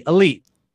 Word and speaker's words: Elyyt: And so I was like, I'll be Elyyt, but I can't spell it normal because that would not be cool enough.